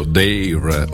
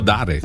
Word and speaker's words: Dare: E cominciamo con la prima novità, che è di Mario Biondi, dall'album L'ultimo Dare, o Dare, così come lui stesso ha detto, si può pronunciare in tutti e due i modi, un po' in italiano, un po' in inglese.